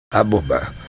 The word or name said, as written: Aboba